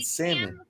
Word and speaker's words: salmon: Larry, the L is silent in salmon.